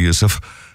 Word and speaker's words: Yusuf: And they know me as Yusuf. I chose my first name now. You know, Joseph was the name I chose. I turned it into an Arabic pronunciation.